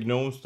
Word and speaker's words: gnomes: Only the gnomes that work in the computer. – Of course, the gnomes. – So many parts. – Yeah, the G is silent, but otherwise, for sure.